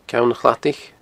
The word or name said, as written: Ceann a Chladaich